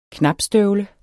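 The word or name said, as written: KNAPSTØVLER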